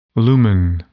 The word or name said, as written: lumen